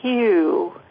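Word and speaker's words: HU: So this last one now is Hue. It's H-U, and it's pronounced H-U-E, Hue. And it's a name for the divine, beloved, source of all that is, God.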